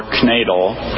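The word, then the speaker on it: knaidel: Canadal, or knadel, is a small mass of leavened dough cooked by boiling or steaming, as with soup, stew, or fruit.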